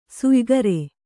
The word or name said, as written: ಶಯ್ಯಾಗಾರ